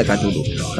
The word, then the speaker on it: Cthulhu: Yo no sé si será en todo el territorio nacional o será solo en Cantabria, pero tenemos una movida que nadie sabe si se dice Cthulhu o se dice Tulu y anda todo el mundo peleándose. – No, bueno, se pronuncia Cthulhu.